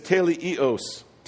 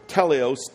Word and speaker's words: teleios: The word for maturity is teleios, T-E-L-E-I-O-S, or teleos, T-E-L-E-O-S, depending on how it's spelled in what manuscript.